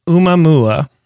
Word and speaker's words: Oumuamua: I believe it's pronounced Oumuamua, and it means thank you.